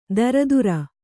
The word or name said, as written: ದರದುರ